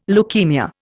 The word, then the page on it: leukaemia: Indian